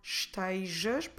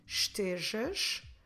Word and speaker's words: estejas: Standard Portuguese goes more estejas, but you can get also people saying estejas. But on the spelling, there's no I. So we spell it como se escreve, E-S-T-E-J-A-S, estejas. If you want to say, don't be sad, não estejas triste, não estejas triste, don't be sad.